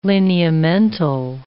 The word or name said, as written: lineamental